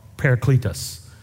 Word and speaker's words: parakletos: The word there, the Greek word there, urge, is a Greek word called parakletos. Like a paracletes, like cleats you run around in. Parakletos is how you say it.